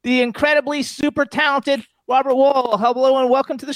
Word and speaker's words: Wuhl: Actually, it's pronounced – my family always pronounced it Wuhl, almost like the side of a building. – It is Wuhl, yeah.